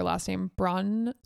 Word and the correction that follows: Brosnahan: I'm saying it wrong. Brosnahan, I think is how you say it.